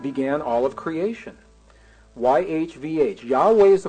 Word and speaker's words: Yahweh: I am convinced beyond any reasonable doubt that this name, YHVH, pronounced Yahweh,